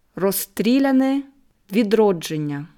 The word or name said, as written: Rosstriljane widrodschennja